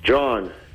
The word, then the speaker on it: John: John. – John. All right, John. Is it spelled weird or is it spelled like J-O-H-N? – J-O-H-N.